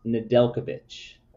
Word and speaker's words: Nedeljkovic: Looks like it's pronounced Nedeljkovic. – Nedeljkovic. I thought that was a J.